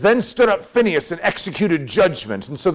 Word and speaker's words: Phinehas: Phineas was typically how we pronounce it. Phinehas could be an accurate way, even though that doesn't sound as good.